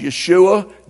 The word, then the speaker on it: Yeshua: In Hebrew, Jesus is pronounced Yeshua, Jesus, but what it literally means is that the Lord saves.